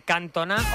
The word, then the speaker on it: Cantona: Mira, nos decía un oyente, Cantona o Ginola no tenían acento y se acentuaban en la última sílaba.